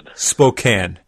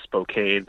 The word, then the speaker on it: Spokane: I know you're going to need to correct me on this because I'm sure I'm going to say it wrong, but is it Spokane or Spokane? – It's Spokane. – Why is that? – Yeah, like a can.